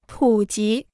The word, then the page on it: 普及: pǔ jí